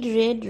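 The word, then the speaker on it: read: लेकिन मैंने रेड क्यों बोला क्योंकि इसको प्रोनाउंस रेड ही करते हैं पर लिखेंगे हम आर ई एड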